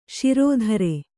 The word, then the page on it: ಷೇರುದಾರ: Kannada